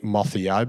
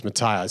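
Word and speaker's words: Motheo: You'd think Mothio, but it's Mateo.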